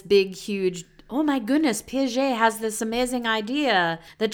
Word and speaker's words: Piaget: I'm also very impressed at how quickly you pronounce Piaget. Like, you just pronounce it very French, very good, and like very quickly.